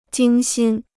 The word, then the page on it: 精心: jīng xīn